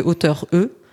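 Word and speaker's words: auteure: Et enfin, employer des formes féminisées, celles qui existaient et qu'on a supprimées ou des nouvelles, comme autrice plutôt que auteur E, qui se prononce de la même façon que auteur, sans E au masculin, peintresse, mairesse, doctoresse.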